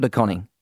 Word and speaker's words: De Koning: Did I pronounce that right? Is it DeConing or DeConing? – Yeah, DeConing, that's right, yeah.